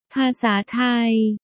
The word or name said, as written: ภาษาไทย